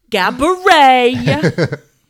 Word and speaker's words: Gabourey: He's like, Gabourey.